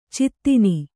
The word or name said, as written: ಛೇದನ